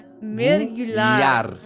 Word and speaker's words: mergulhar: Acabage mergular? What is that? – I just dove into. – Yeah, but how is my pronunciation? – Mergulhar. – Mergulhar. – Yeah.